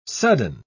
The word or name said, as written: sudden